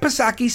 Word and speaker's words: Psaki: But quickly, Jen Pacircleback Psaki, Silent P, of course, she comes out and tries to clean it up.